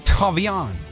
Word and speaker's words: Thauvin: Thauvin.